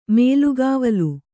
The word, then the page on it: ಮೇಲ್ಗಾವಲು: mēlgāvalu